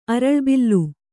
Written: ಅರಳ್ಬಿಲ್ಲು

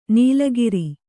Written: ನಾಲಿಕೇರ

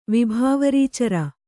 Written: ವಿಭಾವರೀಚರ

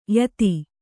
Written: ಯುತಿ

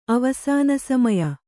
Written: ಅವಸಾನಸಮಯ

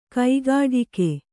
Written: ಕೈಗಡಿಕ

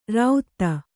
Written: ರಾಉತ್ತ

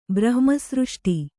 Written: ಬ್ರಹ್ಮಸೃಷ್ಟಿ

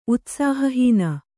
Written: ಉತ್ಸಾಹಹೀನೆ

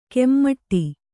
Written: ಕೆಮ್ಮಟ್ಟಿ

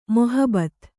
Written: ಮೊಹಬತ್

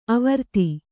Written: ಅವಿರೋಧ

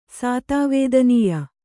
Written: ಸಾತವೇದನೀಯ

